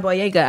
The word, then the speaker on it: Boyega: Even John Boyega, I don't know if I say his name right.